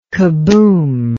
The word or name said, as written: kaboom